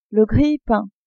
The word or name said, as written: grille-pain